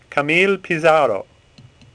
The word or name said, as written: Pissarro